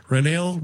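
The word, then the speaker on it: Reniel: because I'm just excited about Raniel Rodriguez. feel like a Les Nessman. Chai Chai Rodriguez. Renel Rodriguez. There, I said it right.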